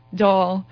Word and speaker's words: Dáil: And apparently with my accent, it's much more difficult to pronounce Dáil. And according to you, I still have some work to do on that, but I'm not saying anything. – Something with the I and the L.